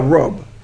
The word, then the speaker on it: Rabb: Okay, he is our Rabb, and that's spelled R-A-B-B, Rabb. And Rabb means the Creator, the Nourisher, the Lord, the Nourisher, Sustainer.